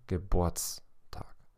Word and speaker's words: Geburtstag: Dacă două cuvinte fac un nou cuvânt, accentul cade pe primul cuvânt. Adică se accentuează silaba din primul cuvânt care se accentuează când cuvântul este și scris separat. De exemplu, Geburt plus Tag face Geburtstag.